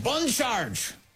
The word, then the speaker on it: Bon Charge: Bon Charge! Really nailing that slightly French pronunciation.